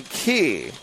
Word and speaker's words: Ki: Looks like it's going to be Min Sung Kai. Actually, they're going to say it's Min Sung Ki. So the long E sound.